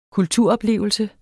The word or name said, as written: kulturoplevelse